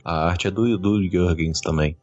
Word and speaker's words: Jurgens: Jurgens. – Ah, em inglês é é Jurgens mesmo. – Não é Jurgen? – É? Não, Jurgens. Jurgens.